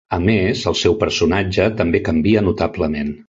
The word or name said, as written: notablement